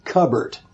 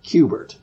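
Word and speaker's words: Q*bert: And somehow that morphed into Qbert, but it was spelled like C-U-B-E-R-T. And I think Ron Waxman supposedly said, well, that's no good because people can pronounce it Cubbert instead of Qbert. So that's when we went with the Q with the asterisk B-E-R-T.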